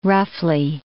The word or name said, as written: roughly